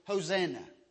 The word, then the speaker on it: Hosanna: We pronounce it Hosanna.